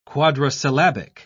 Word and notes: quadrisyllabic: British English

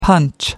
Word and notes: punch out: American English

